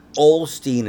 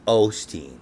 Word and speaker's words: Osteen: It's not Osteen, it's Osteen.